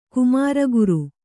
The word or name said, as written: ಕುಮಾರಗುರು